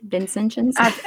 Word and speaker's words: Vincentians: Am I saying that right, Stacy? Vincentians? – It sounds good to me.